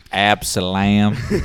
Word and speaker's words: Absalom: I think it's Absalom. Absalom, something like that. – Absalom.